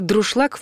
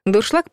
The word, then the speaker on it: дуршлаг: Только если эти два слова реально существуют и называются паронимами, то варианта «друшлаг» в природе пока еще не обнаружено, даже если много лет произносили именно так. Без этимологии вновь не обойтись. Дуршлаг происходит от немецкого «дуршлаг», а еще важно, что ударение всегда в любом падеже и в любом числе падает на второй слог.